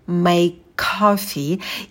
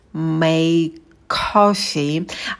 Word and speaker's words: make coffee: И здесь мы звук к произносим только один раз. Make coffee, потому что у нас на стыке два одинаковых согласных звука, неудобно их произносить, поэтому один «к» мы убираем.